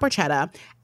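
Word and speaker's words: Borchetta: Big Machine was run by Scott, how do we say his last name? Porchetta? – Yeah. – Porchetta? Like pork? – Yeah. – Anyway, it was run by Scott Porchetta.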